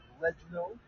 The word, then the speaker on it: Reginald: My name is Reginald, R-E-G. I-N-A-L-D. And Sweeney is spelled S-W-I-N-E-Y.